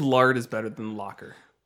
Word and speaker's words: Lockar: L-O-C-K-A-R. The Locker. – Okay. Lockar. Gotcha. – But like, you got to pronounce it like an E. It's the Locker.